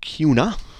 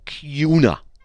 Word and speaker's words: Kuna: K-U-N-A. That's a kind of a neat word for a city. CUNA. It's not tuna, like tuna fish, but it's spelled T-U-N-A. No, K-U-N-A. You'd think it'd be CUNA. No, CUNA. You know, like tuna fish, but it's CUNA. I don't know why. It's the way it is.